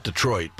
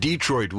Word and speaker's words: Detroit: That's the way he pronounced it. Not Detroit, but Detroit Wheels.